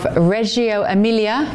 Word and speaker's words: Reggio Emilia: She's set to spend 2 days in the northern city of Reggio Emilia, is that how I pronounce it, Greg? – Yes.